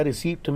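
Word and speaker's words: receipt: रिसीप्ट में P silent होता है.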